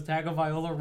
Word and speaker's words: Tagovailoa: He only picked against Tua because Tagovailoa rhymes with turn the ball over.